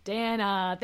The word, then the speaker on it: Dana: Dana. Thank you so much, Dana. We love to see that. Thank you for putting rhymes with banana.